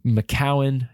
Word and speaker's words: McKeown: Is that first one is Essentialism by Greg McKeown, I think is how you pronounce it, and or McCowan, McEown, I think is how.